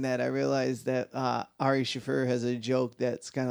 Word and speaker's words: Ari Shaffir: You said Ari Shaffir earlier. Which is how it's properly pronounced. – Oh, well, whatever. – Now you're saying Ari Shaffir.